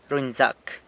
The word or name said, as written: runyzac